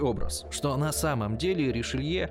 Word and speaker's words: Ришелье: Но даже это не совсем правильное произношение. По-французски нужно говорить Ришелье. А так уж получилось, что первый раз перевели неправильно, и с тех пор Ришелье превратился в Ришелье.